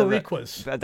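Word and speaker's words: Los Boricuas: We got the Oddities versus Los Barocos. We got the Godfather... – Los Barriquas. – That one. Sorry. – Yeah. – You know, I butchered that.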